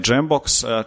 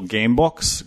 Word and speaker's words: Jambox: Ljudi ono, kada recimo kažu ime prve firme Jambox, često kažu Gamebox, Gamebox je stalno, ali Testom ono zapamte i izgovaraju.